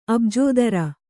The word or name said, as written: ಅಬ್ಜೋದರ